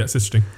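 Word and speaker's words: Sichting: Am I saying that right? – Yeah, Schichting.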